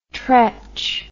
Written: treach

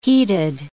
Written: heated

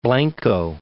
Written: Blanco, Cape

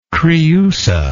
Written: creusa

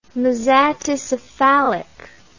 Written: mesaticephalic

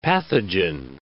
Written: pathogen

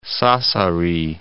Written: Sassari

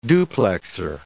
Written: duplexer

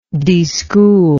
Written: deschool

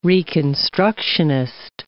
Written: reconstructionist

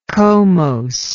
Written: comose

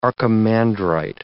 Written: archimandrite

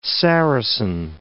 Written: Saracen

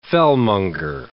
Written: fellmonger

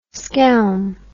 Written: skelm

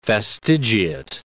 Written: fastigiate

